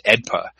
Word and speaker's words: AEDPA: It's AEDPA, and it's normally just pronounced AEDPA.